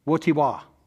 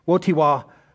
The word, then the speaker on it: Wojtyła: So I had to look this up, and after I looked it up and heard many people actually say his name, I still don't think I have it right, but here we go. There was a pope, John Paul II, and his name was Karol Wojtyła. I'm just telling you, that's how you say it.